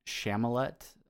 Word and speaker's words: Chalamet: We had Timothy Chalamet. I'm not good with silent T's.